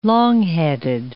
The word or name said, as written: longheaded